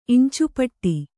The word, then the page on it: ಇಂಚುಪಟ್ಟಿ: iñcupaṭṭi